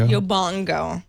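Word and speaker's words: Yobongo: It's like suddenly Yobango is running. – Yobango. – Right. – Oh, boy, Leo. – Is that not how you say it? Yo bango? – Yo bongo. Like bongo drums.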